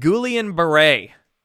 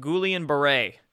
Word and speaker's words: Guillain-Barré: So this second rare side effect is called Guillain-Barré. That's how you say it. Guillain-Barré.